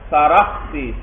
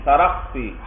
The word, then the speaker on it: سرخسی: یاد رکھنا اس لفظ کا تلفظ بھائی سین کے ساتھ ساتھ را پر بھی فتحہ ہے اور خاص ساکن ہے, سرخسی سرخسی, امام سرخسی بھائی.